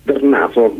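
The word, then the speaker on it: Drnaso: Oh, ma non è molto diversa, guarda, anche avendolo conosciuto in realtà Darnaso nulla più, poi le vocali sono un po' più chiuse, però noi li chiameremo Darnaso e andiamo tranquilli. Come si legge?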